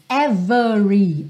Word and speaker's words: every: Every sem o é do meio ser pronunciado, ok? Lembra que a gente já viu em outras músicas every, every, por uma questão de ênfase, tá? Então é possível que esse é apareça pronunciado quando eu quero enfatizar, mas via de regra esse é não é pronunciado.